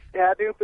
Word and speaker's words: Hadoop: First of all, are we even pronouncing the word right? I've never heard of it. – Yeah, you are. – Okay, all right. – You are. It's Hadoop is the correct pronunciation.